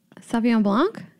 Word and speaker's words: Sauvignon Blanc: Sauvignon Blanc? – Yeah. – Oh, yeah. Okay. So, shit. Okay. Let me think about what I think. – Don't overthink it. – I say Sauvignon Blanc.